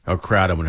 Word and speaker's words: kratom: Kratom, however you pronounce that. Yeah. – I think it's kratom. – Kratom, yeah.